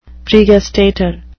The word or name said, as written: pregustator